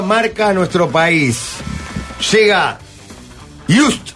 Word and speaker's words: JYSK: Vas a pirar en Just. – Todo. – Just. Es impresionante, Jorge. – Repetí conmigo. – Just. Just. – Just. – Just. Pero se escribe J-Y-S-K, pero se pronuncia...